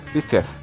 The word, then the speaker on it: Pfeiffer: Pfeiffer, não sei como é que pronuncia esse Pfeiffer dele. – Pfeiffer.